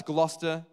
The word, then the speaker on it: Gloucester: The amount of people that get the name wrong, but it's Gloucester. It's apparently an English thing, so that's why it's like that. Who knows?